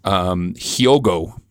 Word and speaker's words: Hyogo: Hyogo.